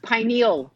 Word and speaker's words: pineal: It's pineal gland. – Pineal, not penile, yeah.